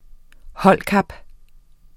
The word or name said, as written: holdkap